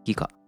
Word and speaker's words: 擬果: 偽の果実と書いて擬果と読みます。